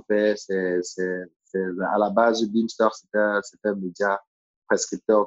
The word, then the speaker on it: Bimstr: Pour ceux qui ne connaissent pas, ça s'écrit B-I-M-S-T-R préfère préciser parce que euh, la façon dont on prononce n'a rien à voir avec la façon avec laquelle on l'écrit. C'est très laid, là b i B-I-M-S-T-R. Bimster.